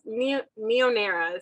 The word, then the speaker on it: Neon Era: So So it's Neonera is how we say it, but it's spelled Neon Era.